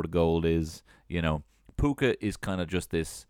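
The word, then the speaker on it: púca: The meaning of puka, pronounced puka, is from the old Irish word puka, which means goblin. And there are many variations of spelling a puka, including P-U-F-O-D-A, P-I-L, P-H-U, Fuka, you fucker.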